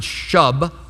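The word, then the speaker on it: shub (שוב): Then there's a word, S-H-U-B. I would pronounce it shub, but in Hebrew it's like shub, shub. I can't even do it.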